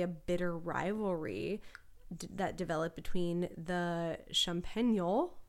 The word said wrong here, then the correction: Champenois: Wait, I'm not going to pronounce that right. – Champenois? – Champenois? Champenois? The Champagne makers.